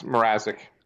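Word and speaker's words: Mrázek: Uh, Flyers acquiring Peter Mazurek from. Uh, is it, did I say that right? Peter Mazurek from. – Uh, no, it's Mrazek.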